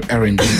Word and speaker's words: R&B: Le R&B. – À savoir dans le contexte quand même, parce que j'ai mal prononcé tout à l'heure. Je dis Airbnb, l'erreur totale.